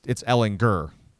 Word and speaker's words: Ellinger: I mean, Tom Herman even corrected a media person at last year's Big 12 Media Days who said Ellinger, and he was like, oh, it's Ellinger. Gurr, hard G.